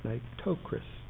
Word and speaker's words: Nitocris: Her name was Nitocris, Nitocria, hard for me to pronounce, Nitocris.